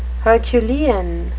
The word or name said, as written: herculean